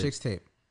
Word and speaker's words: Chixtape: No. If you're gonna say it right. – Chixtape.